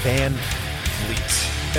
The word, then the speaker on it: Van Vliet: And yes, that's how you pronounce it. I know there's an I in there for some reason. I don't know why my Dutch ancestors did that so many generations ago. But yes, Van Vliet.